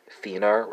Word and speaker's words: finner: Spelled F-I-N-D-E-R. Swedish speakers, Norwegian speakers, you know you have the same spelling, F-I-N-N-E-R, but it's pronounced fina and finer, respectively, right, for the two languages.